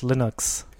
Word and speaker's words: Linux: Und die Amis sagen, by the way, Linux und nicht Linux. – Linux? – Ja, Linux. – Linux? – Nein, doch. Da gibt's doch dieses bekannte, Linux, sagen so. Pronounce Linux as Linux. – Ja. – Von Herrn Torvalds.